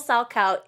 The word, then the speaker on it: Salchow: Because I've watched figure skating most of my life, and, like, having what a triple salchow is explained to me, I'm like, oh, and I know how it's spelled now, because it's subtitled. I'm like, oh, it's spelled like Sachow?